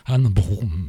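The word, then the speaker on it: Ane Brun: Alors je crois que la prononciation exacte, Aurore, est Anne Brun.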